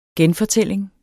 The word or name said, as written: genfortælling